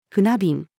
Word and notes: 船便: female

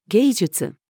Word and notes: 芸術: female

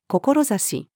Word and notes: 志: female

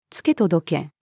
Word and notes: 付け届け: female

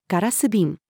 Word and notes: ガラス瓶: female

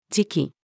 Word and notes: 時期: female

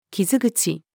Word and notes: 傷口: female

